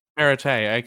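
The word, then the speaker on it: Perrottet: Side note, Ardeet, I'm pretty sure you just informed me that I've been mispronouncing the New South Wales Premier's name wrong. I've always gone and called him, you know, Premier Perriott. Is that not it? – Perriott, yeah. – Perite, okay, whoopsies.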